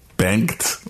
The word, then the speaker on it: Bengt: B-E-N-G-T. – Bengt?